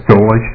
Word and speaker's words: Dolejsi: Madam Chair, committee members, my name is Frank Dolich. That's spelled D-O-L-E-J-S-I.